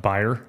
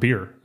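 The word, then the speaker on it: bier: I got to thinking about how Jesus comes up and he touches the, you know, all the way up to this point, I thought it was pronounced buyer. It's pronounced beer. I didn't know that.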